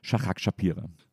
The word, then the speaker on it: Shahak Shapira: Herzlich willkommen, Shahak Shapira.